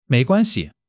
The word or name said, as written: mei2 guan1 xi5